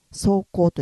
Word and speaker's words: 霜降: 霜が降りると書いてね、霜降と読みます。